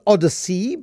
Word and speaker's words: Odyzzey: So I mean you you pronounce it Odyssey, but it is spelled Odyssey.